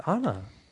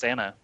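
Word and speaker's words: Ana: Yeah, they spelled it a very weird way to be pronounced Anna, in my opinion. But yeah, officially it's Anna.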